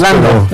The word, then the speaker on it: Landau: Pero una carta exige la renuncia de Marco Rubio y de Christopher Landau, no sé cómo se pronuncia en inglés. – Landau. – Pero es como es un apellido alemán, confunde.